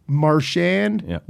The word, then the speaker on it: Marchand: He says, I have notes from the World Junior days where he says, yes, my name is pronounced Marchand, rhymes with sand.